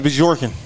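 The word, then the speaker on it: Bjorkgren: See how you pronounce it? – Jorkin. – His name is Bjorkin. – Yeah, Bjorkin.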